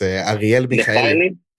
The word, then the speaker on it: Michaeli: And the guy's called Ariel McKaylee. I might butcher that sucker name. – It's actually in Hebrew, so I can say his name in Hebrew. It's Ariel Michaeli.